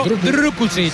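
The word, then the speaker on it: Drkušić: Hay que pronunciarlo Drukušić.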